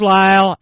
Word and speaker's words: Lisle: Signs, spelled S-I- G N S P O Box 1-2-2. Town is Lyle and it's spelled this way. L I S L E.